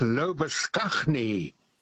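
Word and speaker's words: Labuschagne: Lobashane. – Oh, yes. – Now, Mr. Lobashane's name in South Africa, where I spent my youth, was and still is pronounced as Lobaskagni.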